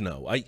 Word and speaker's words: Ngo: The G is silent. It is no. Good. Thank you, Borrowway. I thought it was Andy. No.